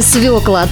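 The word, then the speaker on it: свёкла: Сахарная свекла. Так правильно называется этот корнеплод. В существительном ударение всегда падает на первый слог.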